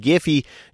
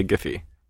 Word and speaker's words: Giphy: We say Giphy. – You say Giphy? – Hard G.